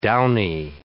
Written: downy

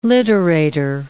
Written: literator

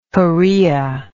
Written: choreoid

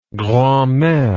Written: grandmere

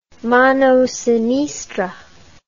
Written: mano sinistra

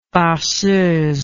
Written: farceuse

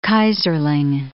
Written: Keyserling

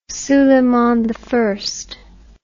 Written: suleimani

